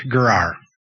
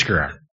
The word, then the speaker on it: Gerar: That's how you say it. I listened to it like four times this week, and I promised I would remember how to say it, and I already forgot how to say it, but I think it's Gerar.